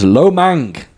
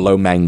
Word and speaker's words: Lo Meng: That is Lo Mang, or Lo Meng, depending on how you say it.